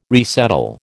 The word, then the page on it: resettle: American English